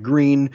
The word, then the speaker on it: Gruene: which is in this small town called Green. It's spelled like German G-R-U-E-N-E.